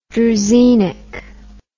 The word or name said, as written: druzhinnik